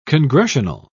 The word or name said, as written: congressional